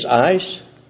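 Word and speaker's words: ace (εἰς): Sometimes pronounced instead of ace, ice, into.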